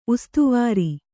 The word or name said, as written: ಉಸ್ತವಾರಿ